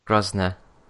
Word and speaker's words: Grosvenor: And I believe it's the Hilton Grosvenor. – Grosvenor. – It's got a V in it. – Yeah, I know, but you don't pronounce it.